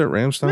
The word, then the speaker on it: Rammstein: Rammstein? – Yeah, Whatever.